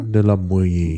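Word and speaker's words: de la Boë: De la Boye. – De la Boye. – Boye ist, die, ist das Wort für Holz. Heute heißt es auf Französisch Bois. Aber es wurde mal Boye ausgesprochen.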